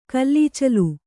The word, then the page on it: ಕುಲಾಚಲ: kulācala